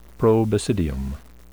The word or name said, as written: probasidium